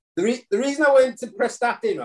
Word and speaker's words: Prestatyn: And you went to go play back in the Welsh Premier with, is it Prestatyn Town? Is that how you say it? – Prestatyn.